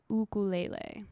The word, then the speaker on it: ukulele: By 1900, the sound of the ukulele was ubiquitous across the islands, or is pronounced by Hawaiians as ukulele.